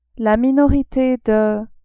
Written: la minorite de

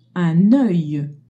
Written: oeil

